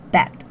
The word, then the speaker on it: bed: 我们最常见的床就是 bed，注意一下这个 e 的发音是小 a，我们叫做小 a 哈，就是呃口型是中等吧，不是特别大，也不是特别小的哈。